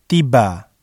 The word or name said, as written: tiba